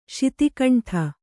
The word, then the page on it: ಶತಖಂಡ: śata khaṇḍa